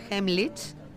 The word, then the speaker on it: Heimlich: Heimlich, ¿lo he dicho bien, Raquel? – Sí, qué importante, correcto.